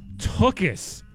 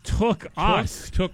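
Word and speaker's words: tuchus: Anthony tweets here, it's pronounced Tuckus. Like took us.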